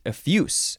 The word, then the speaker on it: effuse: Now this one, because it's an adjective, you would pronounce it effuse. Not with a Z sound, with an S sound.